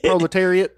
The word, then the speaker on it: proletariat: Like rhymes with chariot, proletariat.